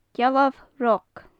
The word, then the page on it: gealbh roc: gaelic